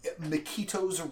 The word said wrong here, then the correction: Michiko: Reign of Truth? – Michiko? – There we go.